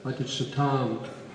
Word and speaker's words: Madhuchutam: And another version is Madhuchutam, the long A, Madhuchutam, which means words having drops of honey.